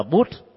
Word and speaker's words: Buddha: Trong khi đó trong tiếng Sanskrit Pali đọc là Put, tại vì chữ A ở cuối từ đó được gọi là âm câm. Thay vì đọc là Buddha thì đọc là Bút.